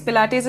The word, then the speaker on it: Pilates: When you say yoga lattes, it's not pronounced as a latte like that that you have at the cafe. It's lattes like Pilates. Pilates is there's this guy called Joseph Pilates.